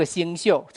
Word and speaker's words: 宿: 这个宿两个读音，一个念宿舍的宿啊，念这个宿就要念，就念这个星宿、辰宿就要念宿啊。